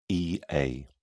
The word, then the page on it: ea: British English